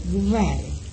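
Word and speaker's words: kvar: Se escribe K, V, A, R. Y la K inicial, ponemos los labios para pronunciar la letra K, y en vez de la K pronunciamos la D, así, var, var. Aspirando un poquito y pronunciamos la D, var, que quiere decir cuatro.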